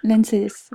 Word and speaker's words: ANSES: L'ANCES, oui.